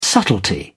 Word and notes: subtlety: British English

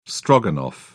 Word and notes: stroganoff: British English

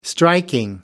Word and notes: striking: American English

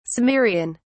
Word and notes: cimmerian: British English

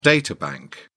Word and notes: data bank: British English

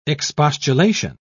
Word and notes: expostulation: British English

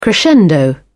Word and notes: crescendo: British English

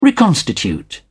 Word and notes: reconstitute: British English

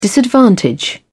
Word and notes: disadvantage: British English

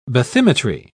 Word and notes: bathymetry: British English

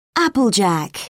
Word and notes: applejack: British English

